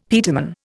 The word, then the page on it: peterman: Indian